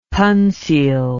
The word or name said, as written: pansil